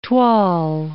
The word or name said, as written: toile